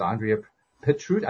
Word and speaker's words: Andrea Petrut: Her name is Andrea Petrut.